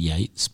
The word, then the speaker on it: Yeats: or Project Yeats, Y-E-A-T-S, which I guess if they've named it after the Irish poet and playwright, then I think that's pronounced Yates.